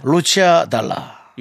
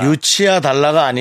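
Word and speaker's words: Lucio Dalla: 루치아 달라. 유, 루치아 달라. 유치아 달라가 아니고요. 루치아 달라.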